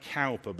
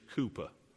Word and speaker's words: Cowper: Is that there's a hymn by William, it sounds like Cowper, but it's actually pronounced in English Cooper.